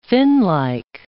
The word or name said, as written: finlike